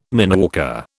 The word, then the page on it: mineworker: British English